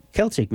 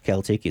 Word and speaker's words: Celtic: I mean, you know, we're going to, because of the Irish festival, I decided to play a little bit of Celtic music. – Nothing wrong with that. – And which is hard to say for me because in French, Celtic is Celtic. – Celtic. – Yeah, it's a soft C.